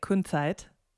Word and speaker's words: Kunzeit: So now you've got this new band, which I love pronouncing in a German way, Kunzeit. – That's perfect.